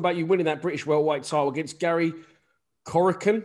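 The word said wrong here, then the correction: Corcoran: Have I said that right? – Kokoran.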